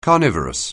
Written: carnivorous

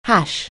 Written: hash

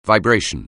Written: vibration

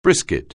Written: brisket

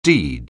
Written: deed

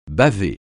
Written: baver

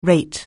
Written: rate

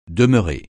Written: demeuré

